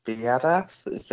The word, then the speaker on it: Biadasz: Um, again, apologies for any uh, pronunciation here, but we have got Tyler Biadasz. That sounds a bit like badass, which is possibly the best name in the whole draft.